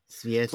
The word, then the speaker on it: cvijeće: Cvijeće. – Flowers, yeah, cvijeće, right? So a TS sound at the beginning of that C.